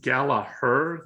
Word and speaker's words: Gallagher: Actually, I think it's Gallagher. The second G is silent. I've been saying it wrong.